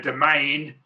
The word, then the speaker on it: demesne: where you have something called a domain, which is spelt as demesne, which is a weird word.